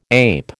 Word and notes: ape: American English

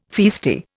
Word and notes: feisty: Indian

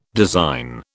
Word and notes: design: British English

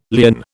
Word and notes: lierne: British English

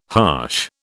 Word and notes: harsh: British English

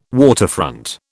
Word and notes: waterfront: British English

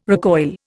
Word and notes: recoil: Indian

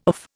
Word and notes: of: Indian

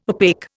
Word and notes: opaque: Indian

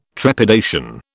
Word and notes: trepidation: British English

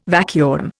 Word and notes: vacuum: Indian

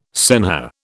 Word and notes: senhor: British English

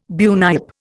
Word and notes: bunyip: Indian

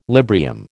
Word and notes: Librium: American English

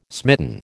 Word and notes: smitten: American English

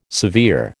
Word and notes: severe: American English

